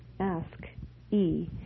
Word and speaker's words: ASCII: You may have heard the term ASCII, pronounced ASC-E, from computer users.